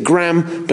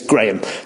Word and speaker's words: Graham: He pronounces it Graham, but that's because he's American. It's Graham. So Graham Elwood and Chris Mancini,